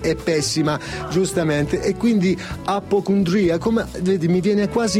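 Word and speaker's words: Appocundria: Scusami, no, fammi sentire la pronuncia esatta di apocondria. – Apocondria. – Sì, poi dipende quanto sei arrabbiato, quanto sei, però è apocondria.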